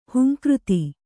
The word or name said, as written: ಹುಂಕೃತಿ